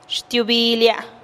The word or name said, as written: xtiubilia